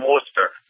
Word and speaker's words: Worcester: But as Heather correctly pronounced, from Worcester. – Ah, okay. – That sounds a lot like Sarasota.